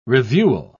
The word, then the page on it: reviewal: British English